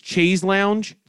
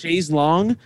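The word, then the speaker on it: Chaise Longue: The song is called, it reads Chaise Lounge, like the part of a couch, but it's pronounced Chaise Long.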